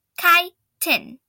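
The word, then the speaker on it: chiton: It's a chitin, pronounced chitin.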